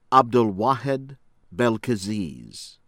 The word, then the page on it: Abdelouahed Belkeziz: American English